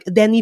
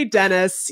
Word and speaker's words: Denis: He's a lot of like, Denis Villeneuve. Never going to say Dennis.